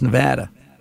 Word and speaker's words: Nevada: It's not Nevada. It's Nevada.